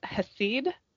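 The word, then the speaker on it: Hesed (חסד): Did you say his seed or hesed? What's hesed? – H-E-S-E-D. Hesed. I don't know if I'm saying it properly. I'm probably not. I learned some words in Hebrew a gazillion years ago, but this was not one of them. – That's probably right.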